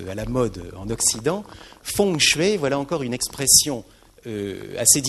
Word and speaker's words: feng shui: Elle est faite, je la prononce ici en chinoise, elle est faite de deux mots chinois. Feng, c'est le vent, F-E-N-G, transcription, et shui, c'est le mot que nous avions vu tout à l'heure, qui désigne l'eau, S-H-U-I.